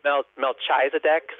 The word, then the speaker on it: Melchizedek: It's M-E-L-C-H-I-Z-E-D-E-K. It kind of looks like Melchizedek, sort of, but it's pronounced Melchizedek.